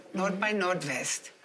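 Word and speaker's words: Nord bei Nordwest: Nord by Nordwest, die wir ja alle sehr lieben. – Es ist schön, dass du es richtig gesagt hast, weil ganz viele sagen immer Nord by Nord, Nord.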